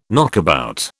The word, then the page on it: knockabout: British English